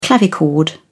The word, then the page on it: clavichord: British English